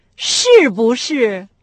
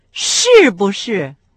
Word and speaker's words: 是不是: Ши-бу-ши. – В этом случае с четвертым тоном произносится только ши, а бу с легким. – Ши-бу-ши.